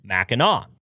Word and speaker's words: Mackinac: but still pronounced it Mackinac.